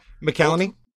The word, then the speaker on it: McCallany: McCallany.